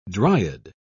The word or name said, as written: dryad